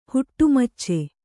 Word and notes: ಹುಟ್ಟುಮಚ್ಚೆ: huṭṭu macce

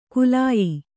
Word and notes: ಕುಲಾಯಿ: kulāyi

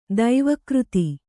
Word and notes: ದೈವಕೃತಿ: daiva křti

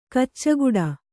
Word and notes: ಕಚ್ಚೆಗಟ್ಟು: kaccegaṭṭu

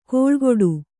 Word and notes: ಕೋಳ್ಗೊಡು: kōḷgoḍu; kannada

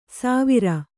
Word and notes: ಸಾವಿರ: sāvira

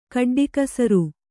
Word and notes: ಕಡ್ಡಿಕಸರು: kaḍḍilasaru

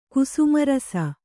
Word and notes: ಕುಸುಮರಸ: kusuma rasa

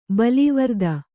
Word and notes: ಬಲೀವರ್ದ: balīvarda

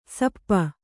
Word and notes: ಶಾಪ: śapa

